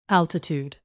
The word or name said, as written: altitude